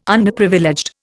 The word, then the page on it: Underprivileged: Indian